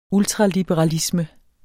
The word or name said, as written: ultraliberalisme